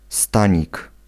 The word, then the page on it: stanik: [ˈstaɲik]